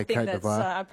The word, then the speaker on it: Côte d'Ivoire: I don't know how to pronounce it, like the real French way. Yeah. Devoir? Côte d'Ivoire? – Yeah, Côte d'Ivoire.